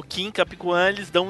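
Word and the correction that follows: Kaphwan: Eu não sei como que pronuncia o nome dele certo. – É Capuan. – Eu sempre falei Kim Capuan. – Kim Capuan. – É capo, é capo.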